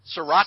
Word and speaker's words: Sriracha: Sriracha.